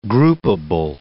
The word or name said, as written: groupable